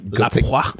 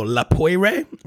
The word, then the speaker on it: La Poire: La Poix. – Take a shot. – La Poix. Grey Goose, La Poix. It's spelled like poire. But the French pronounce it La Poix.